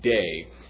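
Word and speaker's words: day (δεῖ): Right after the phrase, then He began to teach them, there's a little impersonal verb that's pronounced day.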